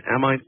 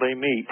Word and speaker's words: Amite: And graduated from, I guess it's Amite uh, High School in Amite, Louisiana. Am I getting that name wrong? – Uh, actually, you are. It's called Amite.